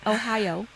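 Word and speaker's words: Ohio (おはよう): So ours are go feed the dogs, good morning, which Don Garrett is Ohio, believe it or not, not spelled the same, but that's how you say it. Ohio is good morning in Japanese.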